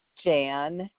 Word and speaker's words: Jan: Jan Bailey. – And it's B-A-I-L-E-Y? – Yeah. – And the first name is J-A-N? – Guilty.